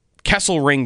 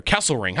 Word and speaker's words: Kesselring: How good does this player, Kesselring, by the way, is how you pronounce his name. The L comes before the R. Kesselring.